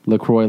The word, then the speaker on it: La Croix: We are even happier that you're pronouncing La Croix correctly. We receive hundreds of calls from fuckboys. – What? – POS. – Wait, is this? – People pronouncing it La Croix like enjoy. – It's La Croix.